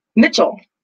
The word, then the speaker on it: Mitchell: Mitchell.